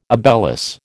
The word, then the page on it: obelus: American English